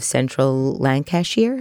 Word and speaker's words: Lancashire: You went to the University of Central Lancashire, is yes.